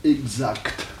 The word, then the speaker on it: exact: Bon, écoutez, actuellement, les deux prononciations existent. Vous ne pouvez pas dire que l'une soit bonne et que l'autre soit mauvaise, voyez-vous. Les deux existent, voyez-vous. Seulement, vous pouvez les juger d'une façon différente. L'ancienne prononciation, si vous voulez, l'ancienne, quelle est-elle? – Exa. – Exa. Voyez-vous, sans que te.